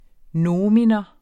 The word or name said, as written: nomen